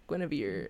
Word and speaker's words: Guinevere: So it starred Genevieve Turner. I think that's how you say her name, Guinevere. – Guinevere Turner, and she co-wrote the film with Rose, I believe it's Troche, and she directed it.